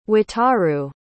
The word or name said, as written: Wataru